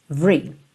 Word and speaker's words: every: Então, essa palavra every Por norma, por padrão, não tem o E do meio pronunciado. Lembra lá do The Police, outra aula que tá aqui na série Aprenda Inglês com Música? Every breath you take. Every. Você pensa como se fosse VR.